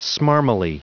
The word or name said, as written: smarmily